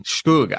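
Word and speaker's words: zhoug: Shkoug. I think it's pronounced shkoug.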